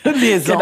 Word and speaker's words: Liaison: Liaison.